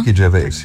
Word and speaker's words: Ricky Gervais: Wie wird er ausgesprochen? Ricky Gervais, ne? – Ricky Gervais, ja.